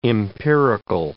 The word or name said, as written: empirical